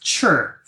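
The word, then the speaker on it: chur (吃): And then the odd thing by is that so in the pinyin spelling, chi is now ci, but the word for eat is spelled chi. But it's also for complicated reason in Beijing dialect is pronounced chur for eat.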